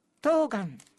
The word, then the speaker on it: 冬瓜: 冬の売りと書いて、はい、なんて読みますか。 – 冬瓜。 – ピンポコピン。